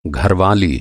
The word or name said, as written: घरवाली